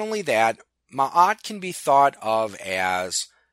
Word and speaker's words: Ma'at: which is spelled MA'AT. It might be pronounced Ma'at. Actually, I think that's how it is pronounced. I seem to recall my professor in ancient Near East religions pronouncing it Ma'at.